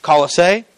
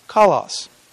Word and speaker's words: Colossae: I didn't take a whole lot of language, but I can tell you that there's several. When you translate over to English, there's several. Colossae, Coloss, Colossus. Or Colossus, because we're Southern, right? No, but the church, Colossae, however that's pronounced, all of those are correct in the English translation.